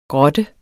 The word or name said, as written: GROTTEN